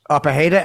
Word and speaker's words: Pereira: Like, you see those type of fights, like, how you say his name, Alex Pereira? – Pajeda, Alex Pajeda.